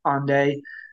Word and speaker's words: Hendaye: They start at the same spot on a beach in Ende, which is spelled H-E-N-D-A-Y-E. That's Ende.